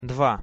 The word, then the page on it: dva: Russian